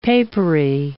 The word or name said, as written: papery